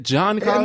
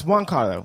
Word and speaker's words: Giancarlo: Is it Giancarlo? – No, it's Juan Carlo. It's Juan Carlo Esposito. We always go back and forth. – Well, it's with the G. – But it's Juan Carlo, right? – Yeah, so it's Juan Carlo Esposito.